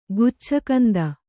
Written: ಗುಚ್ಛಕಂದ

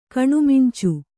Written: ಕಣ್ಮಿಂಚು

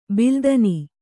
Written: ಬಲ್ಲತನ

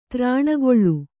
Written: ತ್ರಾಣಗೊಳ್ಳು

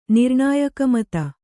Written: ನಿರ್ಣಾಯಕಮತ

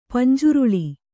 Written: ಪಂಜ್ರೊಳ್ಳಿ

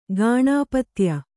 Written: ಗಾಣಪತ್ಯ

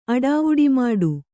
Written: ಅಡಾವುಡಿಮಾಡು

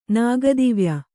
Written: ನಾಗದಿವ್ಯ

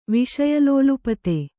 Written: ವಿಷಯಲೋಲುಪತೆ

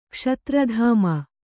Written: ಕ್ಷತ್ರಧಾಮ

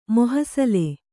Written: ಮೋಹಸಲೆ

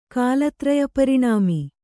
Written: ಕಾಲತ್ರಯಪರಿಣಾಮಿ

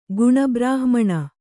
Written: ಗುಣಬ್ರಾಹ್ಮಣ

